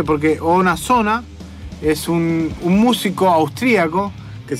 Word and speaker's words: Oona Zoona: Se escribe O-O, o sea, doble O. Na Zona, Z-O-O-N-A. O sea, O-O-N-A, Z-O-O-N-A. Ona Zona.